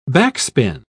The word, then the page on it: backspin: British English